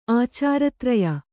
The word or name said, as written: ಆಚಾರತ್ರಯ